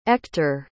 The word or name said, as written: Ektor